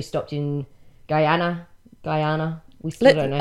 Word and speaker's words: Guyana: I think we're going to decide that our pronunciation for this will be Guyana. – Guyana. – And we acknowledge that there are other ways to pronounce it.